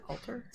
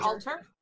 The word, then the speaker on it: altar: Altar? – Altar, yes. – Altar. Like a halter top? – Like a halter. Not quite.